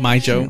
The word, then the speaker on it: mijo: How do you pronounce it? – My Joe.